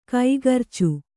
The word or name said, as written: ಕೈಗರ್ಚು